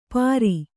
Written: ಪಾರಿ

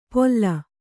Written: ಪೊಲ್ಲ

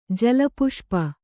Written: ಜಲಪುಷ್ಪ

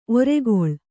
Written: ಒರ್ಕಲು

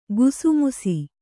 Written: ಗುಸುಮುಸಿ

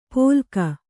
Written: ಪೋಲಕಾ

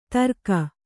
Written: ದೊರಕು

